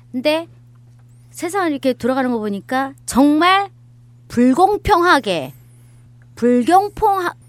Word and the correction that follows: 불공평: 발음이 잘 안 나요. – 네. – 불공평 많더라고요.